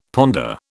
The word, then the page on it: ponder: British English